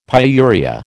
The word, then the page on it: pyuria: American English